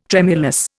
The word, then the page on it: tremulous: Indian